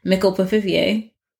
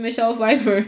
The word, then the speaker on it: Michelle Pfeiffer: She just mispronounced it, and she was like, oh, I mean Michelle Pfeiffer.